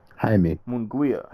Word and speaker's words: Jaime Munguia: Jaime. – Munguia.